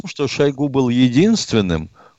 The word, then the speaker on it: Шойгу: назначили Шойгу? Кстати, Сергей Кужгыджич упорно говорит, чтобы ударение делали мы на Шойгу.